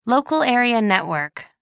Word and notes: local area network: American English

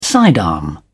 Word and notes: sidearm: British English

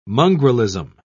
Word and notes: mongrelism: British English

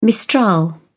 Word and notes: mistral: British English

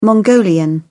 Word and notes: mongolian: British English

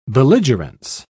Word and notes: belligerence: British English

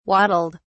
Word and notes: waddled: British English

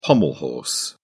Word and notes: pommel horse: British English

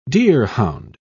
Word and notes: deerhound: British English

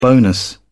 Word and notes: bonus: British English

